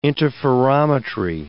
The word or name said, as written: interferometry